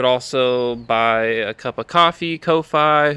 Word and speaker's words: Ko-fi: Coffee. I say coffee, yeah. – Ko-Fi. – Because the logo's a little coffee cup. – Cup of coffee, right? Yeah. I think it's coffee.